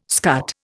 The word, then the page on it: scut: Indian